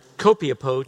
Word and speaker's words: Copiapó: Many of you may remember in 2010, in Copiapó,